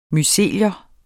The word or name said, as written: mycelium